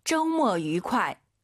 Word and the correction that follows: 周末: 오유는 어예요. 쪼가 아니라 쪼, 모가 아니라 모어, 쪼모어.